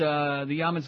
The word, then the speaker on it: Jamin: Jamin, J-A-M-I-N, Yamin. I'm sorry, they don't pronounce the J in Dutch.